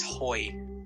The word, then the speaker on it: Hoi: By the way, Mega Man, you pronounced it wrong. It's Hoi. – Hoi. – Yeah, Hoi like Temmie from Undertale.